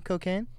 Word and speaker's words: Kokane: I'll start with you, Cocaine. – Oh. I'm super simple to reach. You can reach me on my Instagram at KOKANE_brand. That's how you spell cocaine. Don't spell it the drug related name.